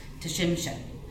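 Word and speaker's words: Tsimshian: He says, it's pronounced Tshimshen.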